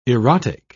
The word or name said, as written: erotic